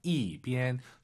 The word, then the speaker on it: 一边: 그리고 숫자 1인데 왜 4성이냐고요? 이, 비엔. 숫자 1 뒤에 1성이 오면은 숫자 1은 4성으로 바뀝니다. 이, 비엔. 이렇게 발음하세요. 이, 비엔.